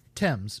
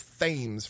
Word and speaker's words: Thames: The novel is notable for its mixture of mysticism, adventure, morality, and camaraderie, and celebrated for its evocation of the nature of Thames Valley. – I mean, Thames, but yes. And also, I pronounced that as Thames for the longest time because I'd never actually heard it pronounced.